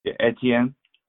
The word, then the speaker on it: Etienne: Etienne.